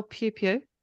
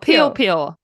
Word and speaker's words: Piopio: Question, how do you spell Pew Pew? – P-U-E-P-U-E. – P-I-O-P-I-O. – Oh, peel, peel.